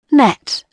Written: net

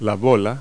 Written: ball

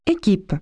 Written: team

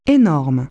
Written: huge

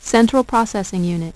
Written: central processing unit

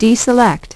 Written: deselect

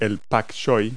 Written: bok choy

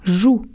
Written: joue

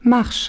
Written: marches

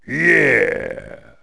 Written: yeah

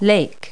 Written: lake

